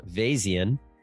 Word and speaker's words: Vazian: Ari is the CEO of Vazian, which rhymes with Bayesian.